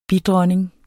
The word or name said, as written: bidronning